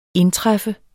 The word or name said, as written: INDTRUFFEN